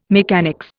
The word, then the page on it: mechanics: Indian